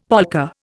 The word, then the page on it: polka: Indian